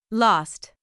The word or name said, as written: lost